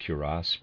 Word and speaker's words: cuirass: I'm pronouncing that cuirass, readers aside, is spelled C-U-I-R-A-S-S. C-U-I-R-A-S-S, I'm pronouncing that cuirass.